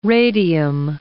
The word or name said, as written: radium